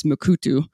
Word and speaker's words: Mukurtu: It's spelled Mukurtu, but it looks like off their website it's Mukutu.